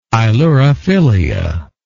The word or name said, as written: ailurophilia